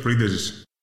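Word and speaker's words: Printezis: Also natürlich sagt man da oft Printesis. Ah, Yorios Printesis. – Und Baba. Yorios Pridesis. – Printesis. – Ja, aber ich glaube, das stimmt schon, dass man das eh schnell spricht bei ihm.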